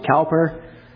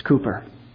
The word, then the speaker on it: Cowper: Some people pronounce it Cowper. It's Cooper.